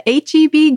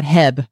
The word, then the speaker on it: HEB: Which I'm just going to point out, I did not know you pronounce that HEB. When I've seen people talk about it, I've always thought they're talking about Heb, like the Heb grocery store. And people say HEB.